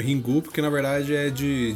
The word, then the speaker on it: Ringu: É, eles têm essa pronúncia diferente do inglês. Então, ring, eles pronunciam ringu.